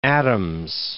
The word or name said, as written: Adams